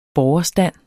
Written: borgerstand

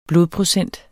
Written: blodprocent

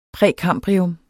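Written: PRÆKAMBRIUM

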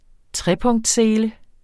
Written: TREPUNKTSSELE

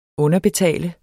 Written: underbetale

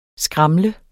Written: SKRAML

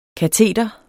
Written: kateder

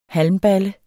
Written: halmballe

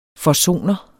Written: forsoner

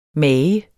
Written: mager

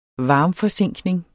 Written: varmforzinkning

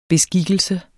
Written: beskikkelse

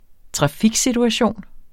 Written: trafiksituation

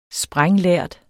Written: sprænglærd

